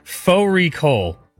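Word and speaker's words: fårikål: Fori Kol, which is a mutton and cabbage. It's spelled like Fari Kol.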